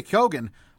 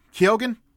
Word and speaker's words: Keoghan: Barry Keoghan of Banshees. Keoghan. Never will pronounce that right on the first try, but he's there as well.